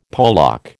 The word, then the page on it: polack: American English